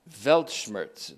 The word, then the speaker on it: Weltschmerz: It's Weltschmerz. Weltschmerz, it's how it's pronounced. There are W's or V's, of course. Weltschmerz.